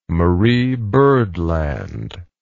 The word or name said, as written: Marie Byrd Land